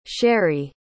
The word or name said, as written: cheri